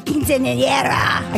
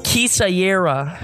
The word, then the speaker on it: quinceañera: I've never been to a quinceañera. I can't say it right. – Yeah. – He doesn't let me say it. Look at his face. He's like, well, at first she pronounced it Kisayera.